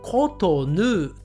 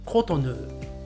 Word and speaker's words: コトヌー: はい、えこれはかなり日本語だと違います。日本語ではコトヌーと言います。コトヌー。